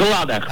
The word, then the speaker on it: складах: ударение остается неизменным и падает на гласную букву А в корне, поэтому правильно говорить еще раз как? – В складах.